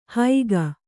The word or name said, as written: ಹೈಗ